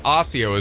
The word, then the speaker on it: Osseo: You mean Osseo? – Osseo. – Osseo. – Osseo is the correct pronunciation.